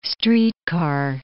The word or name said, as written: streetcar